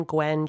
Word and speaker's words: Gwen: My last name is spelled E-Y-S-T-E-R, first name Gwen, G-W-E-N.